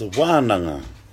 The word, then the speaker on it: wānanga: So the kupu of the day is wānanga. W long A-N-G-A, no, N-A-N-G-A. W long A-N-A-N-G-A.